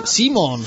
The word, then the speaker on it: Simmons: Pero es que Simon es muy fácil de pronunciar. – Simons. Terminan de S, no es Simon, Simon.